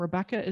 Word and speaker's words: Rebecca: And Rebecca is spelled R-E-B-E-C-C-A.